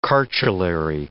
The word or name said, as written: chartulary